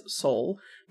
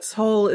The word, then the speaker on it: Seoul: Americans will pronounce the city as Seoul, but Seoul is closer to how it actually sounds, so that's why you're going to hear my unusual inflection.